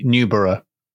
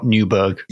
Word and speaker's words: Newborough: I think that's probably Newborough. Not Newburgh. – Yes. – Just if you want to record a drop-in, because he's also Newbury, so it's probably not Newburgh with a hard G.